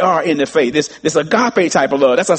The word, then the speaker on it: agape: It's this third, it's this fourth love, and you all, many of you have heard of it, agape, A-G-A-P-E, agape, or sometimes it can be pronounced agapeo, A-G-A-P-A-O, either one of those, agape or agapeo.